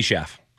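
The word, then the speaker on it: Schaaf: Am I saying that right? Schaff? Libby Schaff? – Yeah, that's correct. Libby Schaff.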